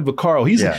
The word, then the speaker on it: Vaccaro: Guy named Sonny Vaccaro. Is that how you say his name? Sonny Vaccaro. – Yeah. – Sonny Vaccaro.